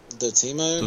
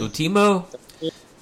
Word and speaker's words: Dotemu: How you pronounce this? – Dotemu. – Dotemu,